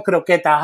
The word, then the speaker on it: Croqueta: Karla Croqueta, ho. – Croqueta. – Gotta roll the R's.